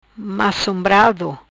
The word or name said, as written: Macombrado